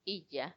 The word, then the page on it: iyya: Macuiltianguis